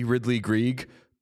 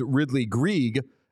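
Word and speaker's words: Ridly Greig: I know it to be Ridley Greig because I'm an effusive pronunciation guide checker, and both the NHL pronunciation guide, which is always bang on, and Hockey Reference, which I think uses the pronunciation guide, has Ridley Greig.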